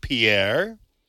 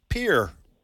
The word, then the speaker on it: Pierre: The pronunciation of the word that you spelled, P-I-E-R-R-E, depends on whether it's a French name or the U.S. city. As a French name, it is pronounced Pierre, while the capital of South Dakota is pronounced Pierre.